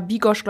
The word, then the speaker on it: bigos: aber wobei das tatsächlich wohl so ist, dass es im Polnischen äh, mehr mit so einem S ausgesprochen wird. – Mhm. Genau. – Aber das Ungarische ist tatsächlich das Sch. Und das ungarischer Bigosch, glaube ich, auch ist da auf dem Weihnachtsmarkt, bist du Weiß richtig.